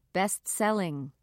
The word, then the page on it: best seller: American English